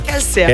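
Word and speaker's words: Kelce: Taylor Swift se je petek poročila nogometašem Travisom, a se izgovori Kelsijem, ne? – Kelsijem, ja.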